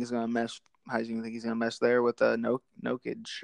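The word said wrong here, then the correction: Jokić: Jokic, yes, the Joker, whatever his name is.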